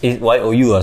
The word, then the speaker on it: 有: It's YOU. I said you. – Yeah, I know. – Okay, don't read it like an English word. Yo.